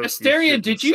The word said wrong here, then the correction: Astarion: Name wrong. – Astarion. – Astarion. – Oh, pardon me. I'm so sorry. – With the long A. Not a soft A, a long A.